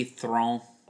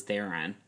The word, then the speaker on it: Theron: But do you know that it's supposed to be Throne? – Throne.